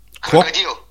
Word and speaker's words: Krokodil: Ich würde jetzt Crocodile spielen. Oder wie würdet ihr das aussprechen? – Krokodil. – Krokodil. Muss immer russisch ausgesprochen werden.